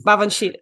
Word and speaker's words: Baobhan Sith: Sorry about my pronunciation, um, which are kinds of shape shifting creatures. Babanshi. Babanshi. Yeah. – Bavanshi. Okay, thank you.